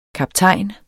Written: kaptajn

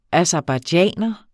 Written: aserbajdsjaner

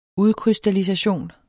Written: udkrystallisation

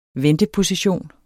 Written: VENTEPOSITION

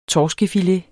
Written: torskefilet

